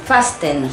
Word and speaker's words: fasten: Fasten. – Sí. – La T es muda. – No se pronuncia.